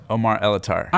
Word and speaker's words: Omar Elattar: Omar Elattar.